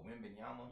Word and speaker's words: Wembanyama: Oh, Wembanyama? – Yeah.